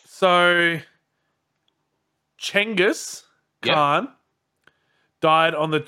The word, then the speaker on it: Chinggis: Or Chinggis, as it's apparently pronounced. – What? I'm sorry, wait. What? – So his name Genghis? is not Genghis Khan or Genghis Khan. It's pronounced almost like a CH. So Chinggis.